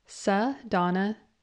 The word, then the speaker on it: Sadonna: You say it like this, Sadonna Heathman. My first name is two parts, Sa and Donna. Sa means from, and Donna means lady, so together it's like saying from a lady.